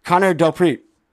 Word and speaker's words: Connor DelPrete: Pronounce my name. Pronounce my name. – Connor DelPrete. – All right, he got it.